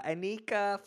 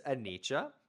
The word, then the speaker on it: Anicha: I want to, Lindsay, ask you about, I mispronounced your name. I said, so, Anika Float Club. Actually, I don't even think I said, Float Center. And you're like, actually, it's Anicha Float Club.